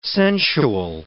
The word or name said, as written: sensual